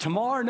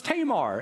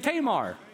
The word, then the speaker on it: Tamar: Is it Tamar? No, it's Tamar. Everybody say Tamar.